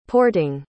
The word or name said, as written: porting